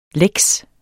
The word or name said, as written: lex